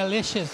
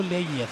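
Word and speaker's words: Leyes: É L-E-Y-E-S. Seria Leches, mas se falar um espanhol mais ali da América Central, fica no Leias. Leias. É. Leias. Maravilha.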